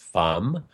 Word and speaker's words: femme: I read Femme, and I know that means, or actually it's really should be pronounced Femme, because it's the French word for woman. And but nevertheless, here in America, we're pronouncing it Femme, I guess.